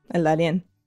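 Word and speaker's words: Darién: Is that how you pronounce it? – Mm-hmm. El Darien.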